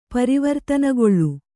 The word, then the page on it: ಪರಿವರ್ತನಕಾಲ: pari vartana kāla